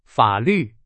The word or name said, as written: 法律